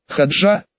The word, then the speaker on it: Хаджа: Хаджа, как хаджа Насреддин. Вспомнил я томик юмористических сказок о веселом пройдохе. Хаджа, поправила, сделав ударение на первый слог, старшая в караване.